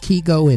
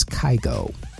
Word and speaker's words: Kygo: And for the record, it's pronounced Kygo in Norway, where he's from. So we go with that over the Americanized version, which is Kygo. Also, in 2018, Sofia Reyes, Jason Derulo, and De La Ghetto released the song 1-2-3.